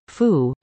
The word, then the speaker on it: pho: According to the book, Hot Sour Salty Sweet, A Culinary Tour Through Southeast Asia, pho, pronounced phu, means noodle, a key ingredient in this soup.